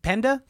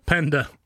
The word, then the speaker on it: Penda: Are you saying panda weirdly? – P-E-N-D-A. Taraji Penda Henson. – Taraji you're saying Penda. It with an accent. – Penda. Penda. Penda. How would you say it? – Penda. – Penda. – No, you're saying like you sound like Penda. – Someone from Asia talking about pandas.